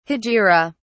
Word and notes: hegira: British English

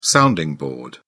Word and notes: sounding board: British English

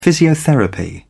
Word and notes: physiotherapy: British English

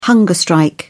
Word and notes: hunger strike: British English

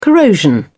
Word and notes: corrosion: British English

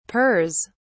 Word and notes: pers: British English

